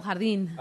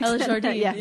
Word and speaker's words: Jardín: El Jardín. – El Jardín. – Can you pronounce it in a Spanish accent? – El Jardín. – Yeah. – Jardín. – Jardín, yeah. – Jardín. The R, too.